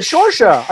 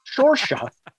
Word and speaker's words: Saoirse: I just nailed it. Saoirse. I did it. – Saoirse. – Just pretend it's X's. Saoirse.